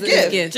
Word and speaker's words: GIF: J is like G-G-G. – Like Jiffy. – G. – Right. Like Jiffy. – So it says it's pronounced with a hard G. – G-G-G-G-Unit. Is that what you about to say? – G-G-G-G-Unit. – So it's GIF.